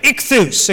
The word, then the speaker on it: ichthus: And so this concept of ichthus, okay, that's how to pronounce it. Let me see if I can get this, yeah. This is an I sound as we have it. This is sort of a K sound. It's a chai.